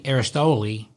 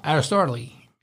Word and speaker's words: Aristotle: My homie Aristoli said, "Who? – Aristoli. – Aristoli. – Aristoli. – Aristotle. Aristotle? – Aristotle.